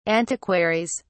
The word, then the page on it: antiquaries: British English